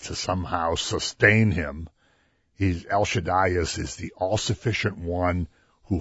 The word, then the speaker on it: Shaddai: And when you put the accent on the last syllable, that means you're making it big, you say Shaddai.